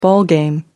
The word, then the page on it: ballgame: American English